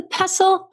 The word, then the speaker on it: pestle: And which I'm not kidding, because every time I think I get it right, I'm like, is that the pestle? Also, is it pestle or pestle? – Pestle. – Nobody knows. – I say pestle. I don't pronounce the T.